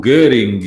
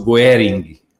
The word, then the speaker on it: Göring: Göring e do Hermann Göring, etc., que ele evidentemente não, não pronunciava assim. É, era quase o Goering, o Goering, assim.